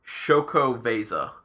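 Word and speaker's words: Xocoveza: God, I don't even want to try to say it. Zocoveza? I don't know. It's X-O-C-O-V-E-Z-A. It's a mocha stout. Oh, it says right here how to pronounce it on the bottle. Shokoveza.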